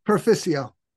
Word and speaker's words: Perficio: Perfico, Perfo? – per- Perficio.